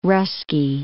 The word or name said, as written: Russky